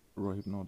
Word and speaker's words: Rohypnol: Is it Rohypnol you were going to say? – Rohypnol, that's the name, that's the correct pronunciation.